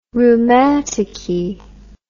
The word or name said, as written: rheumaticky